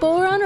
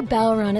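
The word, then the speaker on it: bodhrán: It is pronounced Boron or Balron. It's spelled B-O-D-H-R-A-N, and it used to be something that you would winnow grain with.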